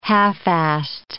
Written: half-assed

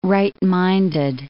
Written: right-minded

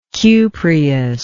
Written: cupreous

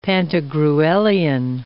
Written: Pantagruelian

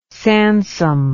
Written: Sansom

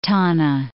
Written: Tanna

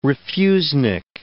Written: refusnik

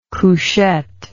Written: couchette

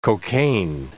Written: cocaine

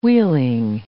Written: wheeling

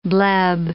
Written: bleb

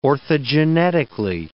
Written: orthogenetically

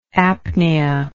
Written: apnoeic

